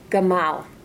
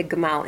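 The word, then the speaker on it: gamal (גמל): Gamal, right? We say gamal in Hebrew. So in Hebrew we have this concept of roots and branches. or hubs and spokes in words. So gamal, gimel, mem, lamed is how you say camel.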